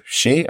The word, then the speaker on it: Shih: You've decided her name should be pronounced she. I think you're right. It's spelled SHIH.